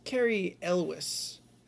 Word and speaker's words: Cary Elwes: That's because Cary Elwes is so good looking.